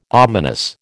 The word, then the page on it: ominous: American English